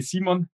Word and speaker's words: Simon: Well, in German, you would say Simon.